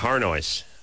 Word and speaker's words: Harnois: Just calling her Arnois, which is much more attractive sounding than Arnois. – That's the way it looks when you look at it. – This is the French pronunciation? – Yeah, that's the French pronunciation.